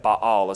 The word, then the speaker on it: Baal: That word means, Bamot means high places, and Baal is Baal, but you would have a little break in it. Baal is how you'd pronounce that.